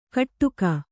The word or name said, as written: ಕಟ್ಟುಕ